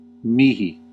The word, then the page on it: mij: /mɛi̯/; /mɛj/